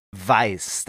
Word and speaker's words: Vyse: Die Agentin heißt Weiß. Das Ganze wird geschrieben. V-Y-S-E.